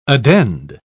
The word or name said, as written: addend